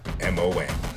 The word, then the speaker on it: Chapman: And just in case you need to know how to spell it, that's Pete with the last name Chatmon. All right, that was episode 44 of Let's Shoot with Pete Chapman.